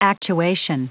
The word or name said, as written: actuation